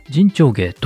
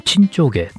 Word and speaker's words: 沈丁花: 人長芸と読むんですけれども、陳長芸と読んでも間違いではないみたいです。